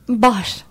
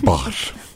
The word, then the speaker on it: Bahr: Tam nasıl telaffuz edeceğim grubun adını? – Ceren'cim. Bahar. – Bahar.